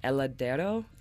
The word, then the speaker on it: Heladero: Heladero.